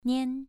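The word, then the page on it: nian: tone 2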